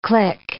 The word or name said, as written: click